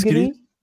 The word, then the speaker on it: Gris: É Gris? – Gris, Gris.